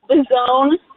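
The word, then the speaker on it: Visogne: So it's the zone. So it's spelled French, but it's actually Italian. So the G is silent. So it's V as in boy, I-S-O-G-N-E.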